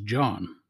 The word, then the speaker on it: Jon: Well, the J stands for my middle name, which is John, and that's spelled J-O-N.